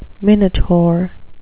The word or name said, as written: minotaur